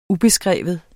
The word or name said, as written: ubeskreven